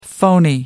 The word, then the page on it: phoney: American English